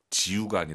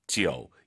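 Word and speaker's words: 九: 3월부터 저희와 함께 하신 분들은 이제 오유는 어우, 러우, 아이유 사이에 역시 오 넣어서 숫자 9, 지우가 아니라 지어우.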